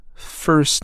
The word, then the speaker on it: First: 那么类似的表述呢，还有名字 first name，头等舱 first class，都是这种表达。它是以 st 这种发音字母组合。类似这种情况呢，那么 t 可以完全不读。